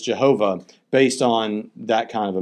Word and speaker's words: Jehovah: So if the Y were as a J and the W in our transliteration were a V, you might get something like Jehovah.